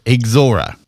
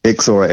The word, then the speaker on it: Ixora: And it's pronounced Ixora. – Ixora.